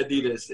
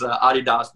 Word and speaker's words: Adidas: It's not Adidas. It's Adidas because the founder, his name was Adi Dassler.